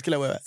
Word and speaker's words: Kilauea: El Kilauea, o cómo se dice. Creo que sí es Kilauea, creo que sí es Kilauea, sí es Kilauea.